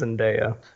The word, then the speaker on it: Zendaya: I am all but certain that it is pronounced Zendaya.